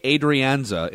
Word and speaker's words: Adrianza: A-Ray Adrianza, it's pronounced, yep.